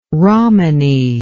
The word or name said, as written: rommany